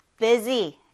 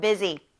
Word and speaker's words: busy: Z 발음 난다 그랬어요. 중간에. Busy. 그쵸? Busy.